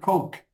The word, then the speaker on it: Koch: And to bring that up, Cardinal Kolch, is it C-O-C-H? How do I pronounce that? Koch? He's a cardinal. He says Vatican. – In the United States, we call it Coke. – Okay, oh, Coke. – And okay. – They pronounce it Coke, but it's kind of silly.